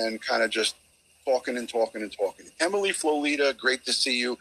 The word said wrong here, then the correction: Flotilia: I always say her name wrong. It's Flotilia She's my very first channel member.